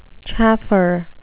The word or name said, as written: chaffer